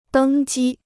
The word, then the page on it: 登机: dēng jī